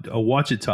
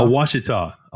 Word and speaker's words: Ouachita: You know, the Ouachita. I never want to say it right. – Ouachita? – Ouachita.